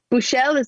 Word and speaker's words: Buschel: Buschel, am I saying your name right? – That is correct.